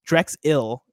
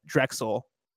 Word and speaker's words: Drexel: It's pronounced more like Drexil than Drexel. So Drexil. Drexil.